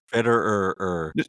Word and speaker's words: Fitterer: The Panthers, they fired their GM, Scott Fitterer. Is that how you say his name? – Fitterer.